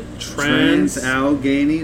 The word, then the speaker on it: Trans-Allegheny: Alright, so for the next one, we're going to the Trans-Allegheny Lunatic Asylum.